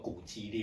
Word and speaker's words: 滑稽: 哦，所以《史记》哦，司马迁哦，把它列在那个《滑稽列传》哦，又念作《古籍列传》哈，上面哦。